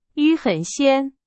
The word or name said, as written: 鲜